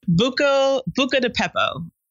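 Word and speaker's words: Buca di Beppo: And I really want to hear about Buca de Pepe. Did I say that right? – Buca de Pepo.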